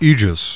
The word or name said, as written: Aegeus